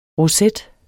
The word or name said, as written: roset